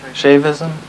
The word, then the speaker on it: Shaivism: Shaivism.